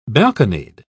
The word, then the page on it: balconied: British English